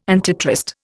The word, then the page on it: antitrust: Indian